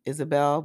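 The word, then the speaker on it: Ysabel: It's Isabel, but it's spelled Jezebel.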